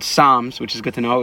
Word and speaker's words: Psalms: Apparently it's not called Psalms, it's called Psalms.